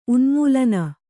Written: ಉನ್ಮೂಲನ

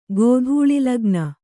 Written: ಗೋಧೂಳಿಲಗ್ನ

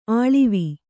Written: ಅಳ್ವು

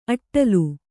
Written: ಅಟ್ಟಲು

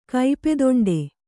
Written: ಕೈಪೆದೊಂಡೆ

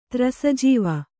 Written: ತ್ರಸಜೀವ

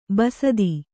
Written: ಬಸ್ತಿ

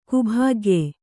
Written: ಕುಭಾಗ್ಯೆ

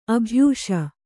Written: ಅಭ್ಯಾಸ

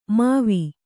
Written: ಮಾವಿ

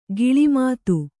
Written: ಗಿಳಿಮಾತು

